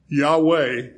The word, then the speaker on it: Yahweh: Yahweh is probably the way to pronounce it.